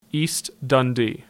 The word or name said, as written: East Dundee